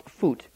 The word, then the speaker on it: Put: The third son was Phut, which is P-U-T, but which is pronounced Phut.